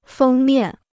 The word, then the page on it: 封面: fēng miàn